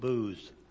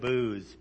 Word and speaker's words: booths: The first is the Feast of Trumpets, and then the Day of Atonement, and the Festival of Tabernacles, or Festival of Booths. Okay, so I got to say that right. I can't say my TH is very good. Sounded like booze, but it was booths, okay?